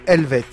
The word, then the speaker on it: Elvet: Helvet. Helvet. C'est des Suisses. – Très bien. – Ça s'écrit E-L-V-E-T.